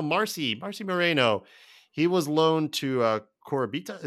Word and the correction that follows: Coritiba: Is that how you pronounce that? Coritiba uh, last year um, in Brazil.